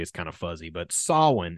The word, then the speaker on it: Samhain: So Danzig is an American heavy metal band led by former, I'm going to say it again, Samhain is how it's pronounced, not Samhain. It's pronounced Samhain.